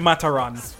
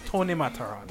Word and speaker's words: Matterhorn: And by the way, I understand that it's pronounced or it's spelled Matterhorn. Mm-hmm. Don't ask me why we pronounce it Matteron. Okay? – Tony Matteron. – That's how it's pronounced. Tony Matteron.